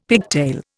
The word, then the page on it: pigtail: Indian